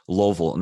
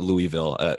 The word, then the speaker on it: Louisville: And I appreciate you using the correct pronunciation as Louisville, not Louisville. My dad's side is from Louisville, and there's a lot of, you know, a lot of distinction there depending on where you're from.